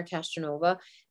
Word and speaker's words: Castronuova: And my name is spelled with the U. It's C A S T R O N U O V A.